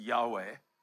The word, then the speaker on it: Yahweh: And it's this thought that Jewish rabbis associate the name of God with the letters YHWH, pronounced Yahweh, we think, with every breath that you take.